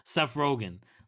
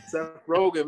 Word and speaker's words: Rogen: Seth Rogen, man.